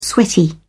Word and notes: sweaty: British English

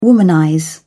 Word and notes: womanize: British English

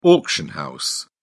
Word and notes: auction house: British English

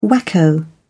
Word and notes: wacko: British English

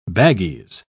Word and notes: baggies: British English

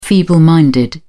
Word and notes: feeble minded: British English

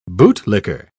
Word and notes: bootlicker: British English

